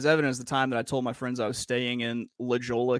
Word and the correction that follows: La Jolla: California, and they're like, "You mean La Jolla?"